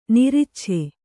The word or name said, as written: ನಿರಿಚ್ಛೆ